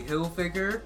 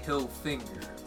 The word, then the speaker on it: Hilfiger: and he thought Tommy Hilfiger was Tommy Hilfinger.